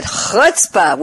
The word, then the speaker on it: chutzpah: And I can pronounce it chutzpah.